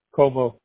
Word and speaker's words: Comeaux: So, Gerald is your first name, G-E-R-A-L-D? – Yes. – Okay. And what is your last name, Gerald? – Como. – How do you spell that? – C-O-M-E-A-U-X.